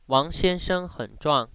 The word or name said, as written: uang